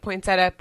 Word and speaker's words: poinsettia: So now I think I do the thing where I'm like poinsettia, poinsettia. Like I say it twice because I don't know what it is. I don't know why we all dropped the I in that.